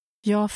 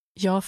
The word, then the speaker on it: Jag: Jag förstår inte. Inte. Inte. Förstår inte. Förstår inte. Jag förstår inte. – Notice how the G sound at the end of I is sometimes dropped in daily speech.